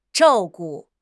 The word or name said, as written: zhao4 gu5